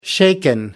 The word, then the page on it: shaken: British English